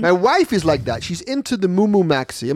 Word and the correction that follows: Mimu Maxi: Am I saying that right? – Mimu Maxi.